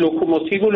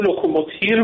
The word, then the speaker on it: لوکوموتیو: حالا آقای رئیسی که خب پروپاگاندا رو پروپاگاندی قبلا گفته بود، لوکوموتیو لوکوموتیر گفته بود که مورد تمسخر واقع شد که ایشون اونقدر با تیر تفنگ سر و کار داره.